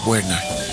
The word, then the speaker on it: Werner: El apellido es Werner, es un apellido de origen alemán, pero en Estados Unidos le dicen Werner, porque la W pues la pronuncian We.